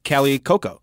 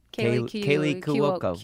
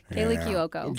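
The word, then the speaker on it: Kaley Cuoco: Well, and I'll say, you know, oh, Kelly Cuoco.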